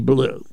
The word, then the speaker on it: Bleu: Well, let me tell you more about Benny Blue. That's spelled B-L-E-U.